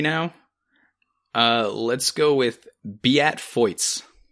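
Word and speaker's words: Beat Feuz: That is my favorite professional ski name, because it's spelt like Beat Fuse.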